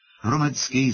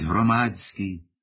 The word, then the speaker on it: громадський: Зокрема, він каже «шляхом» замість «шляхом», «громадський» замість «громадський». Свавільно поводиться він із числівниками, говорячи «сімдесят», «вісімдесят», хоч слід наголошувати на останньому складі.